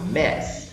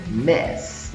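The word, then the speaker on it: Ms: E repare que a pronúncia que eu fiz aí ó, sai com o som do E entre o M e o S, tá? Um MES. Vamos para um exemplo?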